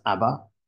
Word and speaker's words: Abba: In Hebrew, in the Hebrew language, the word Father is Ab, although we pronounce it as Abba, it has two syllables. So in that sense, it sounds more like Abba because the first syllable is A and the second is Ba, but they're based on two Hebrew alphabets.